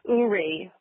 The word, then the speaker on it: Uri: And shortly before she did that, she started calling me Uri, which is the middle part of Lori. I spell Lori, my birth name, L-A-U-R-I-E.